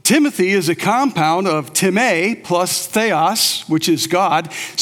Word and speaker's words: timē: So since you know a little Greek, the Greek word for honor is timē. Timē. T-I-M plus a long A. Timē.